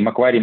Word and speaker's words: Macquarie: а произношение Маквари и Маквори работает, Маквари э, более, мне кажется, английское, Маквори это более австралийское, насколько я понимаю, вот, но да, другие варианты лучше, лучше не использовать.